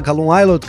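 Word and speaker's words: Callum Ilott: Assim que fala, Calum Islot. Ah, é. Falei certo é, dessa é, vez? É, o Islot também.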